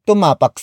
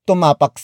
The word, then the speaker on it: tumapak: Which is spelled as H-U-W-A-G. It means do not. Up next, we have tumapak. Tumapak. Tumapak. Tumapak is spelled as T-U-M-A-P-A-K.